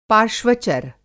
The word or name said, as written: पार्श्वचर